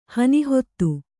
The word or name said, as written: ಹನಿಹೊತ್ತು